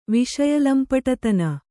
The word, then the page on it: ವಿಷಯಲಂಪಟತನ: viṣaya lampaṭaṭana